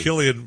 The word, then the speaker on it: Cillian: Cillian Murphy. Yeah, he's a good Irish pronunciation, you know. – You did it right.